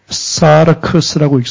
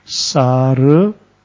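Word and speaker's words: 사르크스 (σάρξ): 육신을 헬라어로 사르크스라고 읽습니다. 사르, 이것은요, K 발음과 S 발음이 동일하게 나요. 그래서 사르크스 이렇게 읽습니다.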